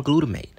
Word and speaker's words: glutamate: MSG is sodium glutamate. That's how you pronounce that.